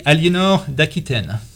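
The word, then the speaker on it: Aliénor d'Aquitaine: How do you say her name in French? – That would be Eleanor d'Aquitaine.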